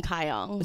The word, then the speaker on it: Kyung: It's like Kyle, but with a N-G at the end. It's super fucking Asian. Okay, so my full name is Yoon Kyung.